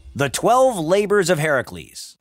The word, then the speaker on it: Heracles: But since we're all about Greek mythology, we will use the proper original Greek pronunciation of the hero's name and call him Heracles.